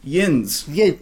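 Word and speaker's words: yinz: I never got past the word yuns. – Yins. – Yins. See, I can't even say this properly. It's hysterical, Yeah. Some people do say yuns, but yins.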